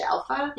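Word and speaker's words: Alfa: They can find me on all social media at Aisha Alpha.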